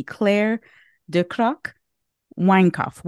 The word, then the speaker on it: Claire Ducrocq Weinkauf: Claire Ducrocq. Weinkauf.